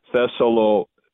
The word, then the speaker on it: Thessalonica: In the Greek, you look at Acts chapter 17, verse 1 in Greek, it says Thessalonica. So the accent is on the I there, toward the on the penult.